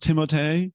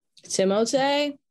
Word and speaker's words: Timothée: His name actually is pronounced Timothée. – Timothée. If you're in France, I'm sure you heard. – Yeah. In France, everyone was always saying Timothée.